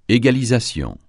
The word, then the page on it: égalisation: francais